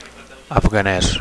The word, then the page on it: afganes: Catalan